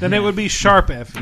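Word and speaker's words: Tokyo Mirage Sessions ♯FE: It is Note. It is Musical Note FE, or Sharp FE, I believe it is.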